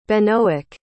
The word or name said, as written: benoic